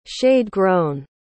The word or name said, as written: shade-grown